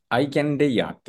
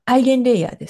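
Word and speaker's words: EigenLayer: これ、英語だと、あの、アイ、アイケンレイヤーって発音するんですかアイゲンレイヤーです。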